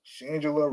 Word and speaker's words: Shangela: Shangela rhymes with Angela.